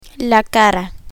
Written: cara

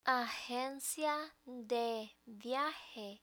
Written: agencia de viaje

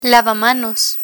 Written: lavamanos

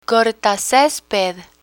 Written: cortacesped

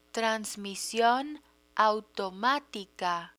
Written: transmision automatica

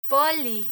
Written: poli

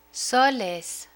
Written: soles

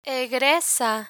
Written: egresa

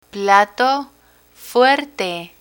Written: plato fuerte

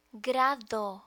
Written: grado

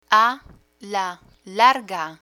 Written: a la larga

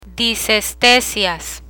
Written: disestesias

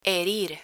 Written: herir

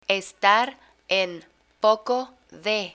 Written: estar en poco de

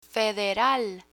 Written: federal

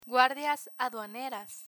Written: guardias aduaneras

